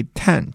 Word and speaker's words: 陈: 读为 ten 这个读音呢，一直延续到近代，